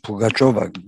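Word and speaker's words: Pugaçova (Пугачёва): Çünkü Yala Pugaçova, Pugaçova diye okunuyor galiba.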